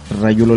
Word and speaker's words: Rayulo: Rayulo Le Pon se escribe R-A-Y-U-L-O, espacio, que es el espacio, Le L-E-C-A-N-I-V-O-N,